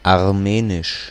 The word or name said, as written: Armenian